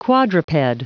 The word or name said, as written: quadruped